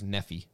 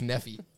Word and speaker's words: Nephi: So, again, and if you look at our linebackers, so Nephi Sewell, he's the linebacker, which it's pronounced Nephi. – Yeah. He's a junior. Lloyd's a junior. – If you're not from Utah. – If you're not from Utah, it's Nephi.